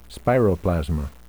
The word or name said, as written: spiroplasma